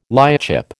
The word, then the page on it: lightship: American English